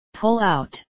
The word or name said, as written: pull out